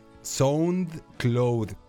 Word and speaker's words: SoundCloud: SoundCloud que como es una palabra en inglés, se las voy a decir como se escribe. Sound Cloud. Sound Cloud, que en inglés se dice Sound Cloud,